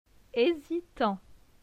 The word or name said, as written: Hesitant